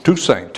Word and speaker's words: Toussaint: Toussaint. – Toussaint. There you go. Two-way. Two-wa. Tell me again. Toi. Toi. Yes. Toussaint. that's the redneck way to say it.